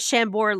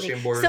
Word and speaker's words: Chambord: Oh, are you not supposed to pronounce the D? – Yeah, I always call it Chambord.